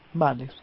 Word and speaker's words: Mahler: Mahler.